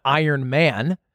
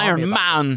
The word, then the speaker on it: Iron Man: You just really ethically react to something, then go off and have a kid, and then and go run an Iron Man, which nobody knows what that is or cares. – It's a weird. You put the emphasis on the wrong part of the Iron Man.